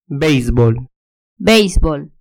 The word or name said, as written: beisbol